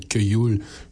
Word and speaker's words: Queyul: Sur Twitter, il y a mon ami Kyoul. Je pense que c'est comme ça qu'il faut le prononcer. Je connais son vrai nom, mais je l'appelle Kyoul. Q-U-E-Y-U-L.